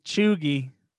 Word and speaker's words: choogy: The word of the day is choogy. What does choogy mean? A catch-all word to describe anything that is basic, uncool, or untrendy. According to the New York Times, choogy, pronounced choo-gy, can be used broadly to describe someone who is out of date or trying too hard.